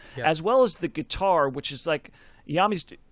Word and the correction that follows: Iommi: Is it Iommi, right? That's, yeah, how you pronounce it.